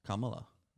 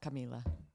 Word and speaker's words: Kamala: Oh, wow. Kamala. – Not Camila. – Kamala. That's what I said.